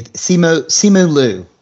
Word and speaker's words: Simu Liu: Give me Simu Liu.